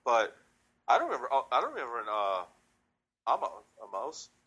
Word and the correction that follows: Omos: Yeah, it's just Omos. I mean, it's not that hard.